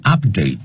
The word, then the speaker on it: update: 他说呢 You must update，刚才念的是 update，是名词啊， requires an update to the latest version，但是当动词的时候念成 update 啊，